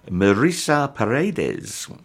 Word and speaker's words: Marisa Paredes: Marisa Paredes. I checked online how to pronounce that in case I said Maria Paredes or Marisa Paredes.